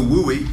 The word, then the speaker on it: WUI: So the WUI, W-U-I, rhymes with FUI.